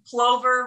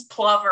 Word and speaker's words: plover: That's like plover versus plover or whatever. Like I've learned it as a plover, but I've heard people say it as like it rhymes with lover.